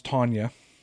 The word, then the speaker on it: Tanja: So Tanja is normally pronounced Tanya. – Tanya, okay. – Mm-hmm. – Really? – Yeah. – Yes. – Nice. – I think that's the German way of spelling it, the ja, the J-A, the ja.